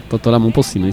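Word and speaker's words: Totó La Momposina: Que é o som de Totó La Mopocina, falei certo? – Totó La Mopocina, isso mesmo.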